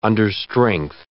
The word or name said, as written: understrength